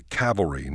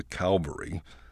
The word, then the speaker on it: cavalry: When people need help, they send for the cavalry, not the Calvary.